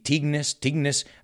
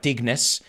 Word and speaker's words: Tignes: Tignes. Well, I pronounced that wrong. I learned the last day. It's not Tignes. It's Tigne.